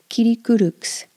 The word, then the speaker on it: Kirikûruks: Ki, ri, ku, ruks. If you can't pronounce that R, use a soft D or even an L. And the K is a soft K again. Kirikuruks. It's almost a G, but not quite.